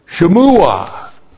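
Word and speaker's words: Shemua (שמועה): Another word from the Old Testament, Shemua. Looks like that in the Hebrew, S-H, Shava, M, long U, Ayin, A-H, Shemua.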